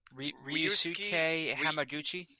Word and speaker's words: Ryusuke Hamaguchi: Oh, sorry, it is Ryusuke Hamaguchi.